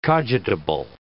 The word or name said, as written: cogitable